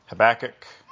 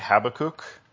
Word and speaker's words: Habakkuk: Maybe if you didn't grow up at Trinity or Hillcrest, you pronounced it Habakkuk, which would be the Hebrew way, but Pastor Gary always said Habakkuk, so that's what I say.